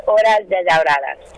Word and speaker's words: Horas Desahoradas: You've got an album out, and forgive my pronunciation, Horas Desahoradas? – Pretty good. It's Horas Desahoradas.